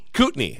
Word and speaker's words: Kootenai: Kootenai. It's Kootenai County, Idaho, which is where Coeur d'Alene, it's up north, it's adjacent to the border where like Spokane, Washington is, Kootenai.